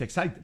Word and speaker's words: Exide: Se escribe Exide, se pronuncia Exide, decía el comercial de las baterías.